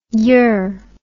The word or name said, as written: yer